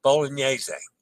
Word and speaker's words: Bolognese: A reminder, the GN is pronounced NY as in lasagna and montaña. Same as the N in Spanish as in mañana. Okay. All right. So Farfalle. And Bolognese. There we go. Bolognese.